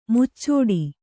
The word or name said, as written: ಮುಚ್ಚಟೆ